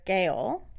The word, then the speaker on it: Gaol: It's actually called the Old Mount Gambier Gaol. Gaol? Jail? How do you say that word? – Well, it's pronounced jail.